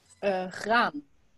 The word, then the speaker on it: graan: How do you say wheat in Japanese and in Dutch? W-H-E-A-T, the cereal. – Graan. – Okay, like grain. It kind of sounds like grain, right? – Yeah. – Okay, and in Japan? – What? – W-H-E-A-T. Like W-H-E-A-T.